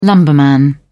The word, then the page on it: lumberman: British English